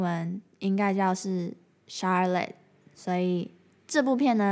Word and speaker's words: Charlotte: 需要更正一下资讯，就是我在最后剪辑的时候发现，我把夏绿蒂这个英文名字讲成 Charlotte，但它其实应该是 S H 的发音哦，是 Charlotte 这样子，所以大家之后在念的时候不要像我一样搞混了。